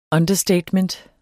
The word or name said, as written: understatement